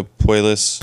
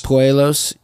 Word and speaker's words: Puelles: Claudio Puelles. – Puelles. – Puelles.